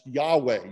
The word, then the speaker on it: Yahweh: Now in Hebrew, the Hebrew word underneath the words the Lord is actually YHWH. Sometimes it's pronounced Yahweh.